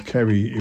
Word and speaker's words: Ceri: And because his name is Kerry, it was put on the wrong list. He ended up on the girls list. Now, his name is spelled C-E-R-I, but... I mean, I had a boss who was Kerry, and that was K-E-R-R-Y.